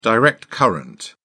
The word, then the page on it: direct current: British English